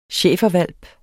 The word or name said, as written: schæferhvalp